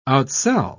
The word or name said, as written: outsell